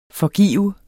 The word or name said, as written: forgive